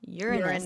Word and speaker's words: Uranus: How about how you say it correctly? – Uranus. – Uranus? Uranus? Like urine? – Yeah. Well, see, pick your poison.